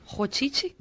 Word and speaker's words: Roditi: how would you say that? – Claudio what? – Roditi? – Rochichi? – R-O-D-I... – It's R, so it's Ho.